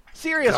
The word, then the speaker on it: Sirius: Sirius rhymes with Prince's song, Delirious.